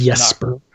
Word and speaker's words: Jesper: That like Jesper Eisling, or however, I think I'm mispronouncing his name, but just Jesper. – Jesper nailed it.